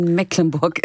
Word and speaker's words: Mecklenburg: Sie sagen Mecklenburg. Das ist ja ganz fantastisch, weil das schafft ja fast niemand sonst. Es handelt sich nämlich um das norddeutsche Dehnungsziel, was kein Mecklenburg ist.